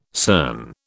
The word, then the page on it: CERN: British English